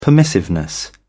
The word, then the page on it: permissiveness: British English